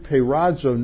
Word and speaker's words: peirazo: The Greek word is peirazo. I don't have that up here on the screen. It's spelled P-E-I-R-A-Z-O.